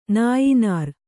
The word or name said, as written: ನಾಯಿನಾರ್